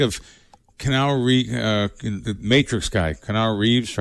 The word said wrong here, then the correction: Keanu Reeves: Am I saying his name right? Kenal Reeves? – Keanu Reeves. – Keanu. Yeah. Keanu Reeves,